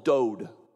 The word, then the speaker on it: dod: If you look in your in this verse, the word love here is a word in Hebrew called dod, D-O-D, that's how you would pronounce it.